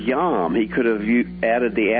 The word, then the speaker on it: yom: The Hebrew word in Genesis 1 is the word yom. And if you were going to spell it with English letters, you'd spell it Y-O-M.